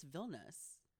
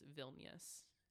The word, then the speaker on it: Vilnius: Vilnius. Pretty sure it's Vilnius. – I think we did Google the pronunciation yesterday and it was Vilnius.